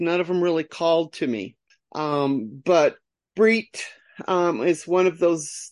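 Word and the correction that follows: Brigid: Her name is pronounced Braheed. Braheed. Okay.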